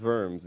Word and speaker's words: Worms: Now, you may say it's W, it's the W's are pronounced as V. I used to call it the Diet of Worms, all right? It's not the Diet of Worms. Worms or Worms is a place, and a diet was longer than the 2 days that most of us know about.